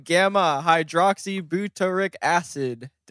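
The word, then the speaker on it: gamma-hydroxybutyric acid: Anyway, today we are talking about gamma hydroxybutyric acid. Did I say it right? – Yeah.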